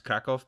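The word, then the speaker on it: Kraków: Krakow is pronounced Krakow, but whenever I say Krakow, I feel like I'm saying Barcelona. I don't have it in me.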